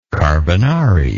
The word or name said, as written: carbonari